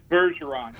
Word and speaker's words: Bergeron: It's Bergeron, yeah.